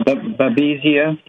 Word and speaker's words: Babesia: I'll spell it B-A-B-E-S-I-A. – Yeah, Babesia. – Babesia.